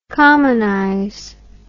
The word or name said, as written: commonize